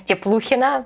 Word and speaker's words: Tepluchina: so its correct pronunciation is Tepluchina, but it's like that's why I'm usually just asking people to call me Natalia and let's stop on this.